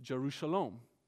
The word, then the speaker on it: Jerusalem: Shalom is the word peace in Hebrew, and Jerusalem is pronounced Jerushalom. So there's a little bit of a play on words when he says, Pray for the peace of Jerusalem.